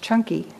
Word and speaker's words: Canki: There's a sutta teaching and a sutta called the Canki, which is pronounced chunky.